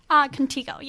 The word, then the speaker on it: Contigo: Joining me in studio from Contigo. Did I say that right? – Contigo, yes.